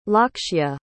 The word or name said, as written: Lakshya